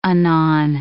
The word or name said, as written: anon